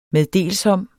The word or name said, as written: MEDDELSOMME